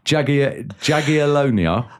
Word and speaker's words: Jagiellonia: They've beaten Jagiellonia. Which is, why are you laughing on pronunciation? That is spot on.